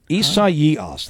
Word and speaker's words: Isaias: And the name of it is Isaias, that's the name, proper pronunciation of the hurricane. Hurricane Isaias.